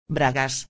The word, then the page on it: bragas: espanhol